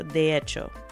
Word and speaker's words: hecho: So de hecho. And hecho is spelled with an H, so it's spelled the letter H, even though it's silent. So de and then hecho, H-E-C-H-O.